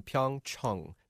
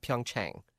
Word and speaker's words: Pyeongchang: And that is the way we're being told to pronounce it, everyone. Pyeongchang, not Pyeongchang. Pyeongchang. Chung.